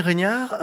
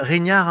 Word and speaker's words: Régnard: Nous recevons Stéphanie Régnard, euh, Régnard, hein, je me trompe pas, euh, parce qu'à chaque fois il paraît que j'écorche les noms, je fais attention.